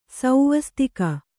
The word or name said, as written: ಸ್ವಸ್ತಿಕೆ